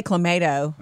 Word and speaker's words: Clamato: Is it Clamato? – We say Clamato.